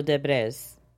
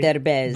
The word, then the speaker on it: Derbez: Eugenio Derbez. – See? – Derbez. – There is no way I would say that. – Eugenio Derbez.